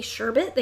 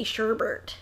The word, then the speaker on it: sherbet: Actually, a lot of times in the U.S., people don't say sherbet, they say sherbert. – Yeah, my family does that. – A lot of people say sherbert. – Yeah. – And that's not how you say it. – No. – It's not even how it's spelled. – There's no second R. – It's sherbet, but everyone says sherbert.